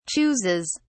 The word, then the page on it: chooses: British English